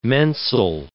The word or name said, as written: mensal